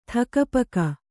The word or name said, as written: ಥಕಪಕ